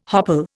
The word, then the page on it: hopple: Indian